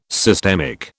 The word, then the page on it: systemic: British English